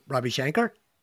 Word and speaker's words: Ravi Shankar: Ravi Shankar. – Is it Ravi Shankar? – Yeah.